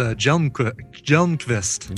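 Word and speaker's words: Hjelmqvist: It's Jelmqvist. – Oh, the H is silent. – The H is silent, yes.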